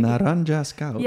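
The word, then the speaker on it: Narayana's Cows: Ja, het stuk, kan, kan je het voor me uitspreken? Sorry. – Naranja's cows. Ja, Dat is hoe ik het zeg.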